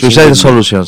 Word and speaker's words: Suicide Solution: Suicide Solution, sí.